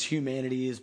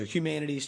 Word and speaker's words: Huemanatees: It's Humanities, but it's spelled HUEMANATEES. So Hugh, HUE, Manatees, MANATEES. So Humanities, check that out.